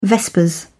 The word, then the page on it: vespers: British English